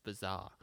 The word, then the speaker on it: Bazaar: Bazar is a magazine. It's pronounced bizarre.